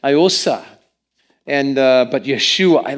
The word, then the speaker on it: Yeshua: How do you say Jesus' name in Hebrew? Yeshua. Yeah, everybody say it. Yeshua.